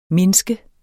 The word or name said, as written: mindske